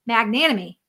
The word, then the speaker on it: Magnanimi: I've got the link up there, but you spell his name Fred Magnanimi. Did I say it right that time? – That's it. Yeah, there just got to say okay.